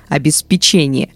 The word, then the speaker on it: обеспечение: Запомнить, где ставить ударение легко. Когда в слове проглядывает печенье, обеспечение, вы сделали неправильный выбор.